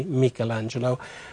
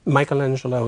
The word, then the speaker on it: Michelangelo: And by the way, the pronunciation in Italian would be Michelangelo. Michelangelo is perfectly acceptable in English, and you'll hear me do both, and probably something in between.